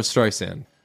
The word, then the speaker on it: Streisand: Barbara Streisand.